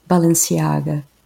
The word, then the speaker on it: Balenciaga: The first one is Spanish, and it's Balenciaga. I don't speak Spanish, but I know that's how it's supposed to be pronounced, so Balenciaga.